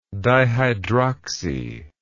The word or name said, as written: dihydroxy